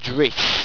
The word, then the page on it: drisse: Owstwecian